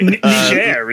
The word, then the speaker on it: Niger: I think it's pronounced... anyway, Niger, isn't it? – Yeah. It's French, isn't it?